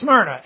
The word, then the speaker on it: Smyrna: But here, it's Smyrna. Amen? Just two syllables.